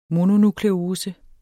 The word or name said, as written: mononukleose